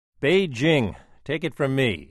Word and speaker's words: Beijing: Spelled with a J but pronounced with a Z, Beijing is rich in history but still shrouded in mystery.